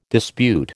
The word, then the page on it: dispute: American English